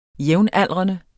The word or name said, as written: jævnaldrende